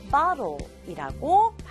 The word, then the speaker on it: bottle: 마찬가지로 bottle 할 수도 있지만 자연스럽게 읽으면 bottle이라고 발음을 해요.